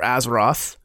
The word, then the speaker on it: Azeroth: One, there's a whole new expansion pack, Battle for Azeroth.